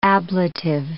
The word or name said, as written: ablative